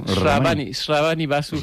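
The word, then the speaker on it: Shrabani Basu: Ramani, sí, ¿está bien dicho? – Ravani Basu.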